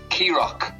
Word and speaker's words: Kerragh: And they're spelled funny. They're spelled K-E-R-R-A-G-H, right? But they're pronounced Key Rock.